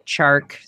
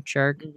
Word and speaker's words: Chark: And second round receiver DJ Chark, sorry, say his name, Chark, will add some much needed speed on the outside.